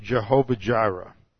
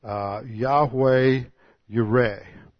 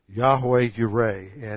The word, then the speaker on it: Yahweh Yireh: The eighth name for God is the term that you've probably heard pronounced Jehovah Jireh, which actually in the Hebrew reads Yahweh Yireh. Yahweh Yireh.